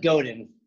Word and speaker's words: Godin: Seth Godin. – Godin. There we go.